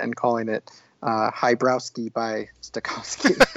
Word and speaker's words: Stokowski: How'd you pronounce his name? – Stokowski? I pronounced it Stokowski because I watched a little documentary on Fantasia and I think that's how they pronounced it.